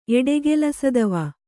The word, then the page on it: ಎಡೆಗೆಲಸದವ: Kannada